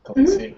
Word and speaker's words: Khaleesi: Well, the new face is, what's his, Khaleesi's, that's how you say her name, right? Khaleesi, mm-hmm.